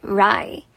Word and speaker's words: wry: 那今天我们要来学两个单字，第一个 wry，w r y wry。是讽刺的、解嘲的，记得这个 W 不发音，wry。